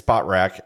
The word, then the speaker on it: Spotrac: so how do we say it correctly? And he said, it's SpotRac, and it's terrible.